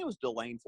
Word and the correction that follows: DeLand: Florida, but I probably probably got that wrong um, Deland, Florida. I probably butcher that.